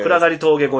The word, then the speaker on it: 暗がり峠: 暗がり峠越えです。 – これ暗い峠と書いてね、暗がり峠って読むんですよね。